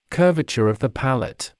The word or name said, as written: curvature of the palate